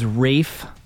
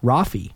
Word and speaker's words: Rafe: I saw the spelling, R-A-F-E, and I pronounced it Rafi.